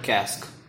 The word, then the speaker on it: casque: The hornbill's helmet is called a casque, which rhymes with mask.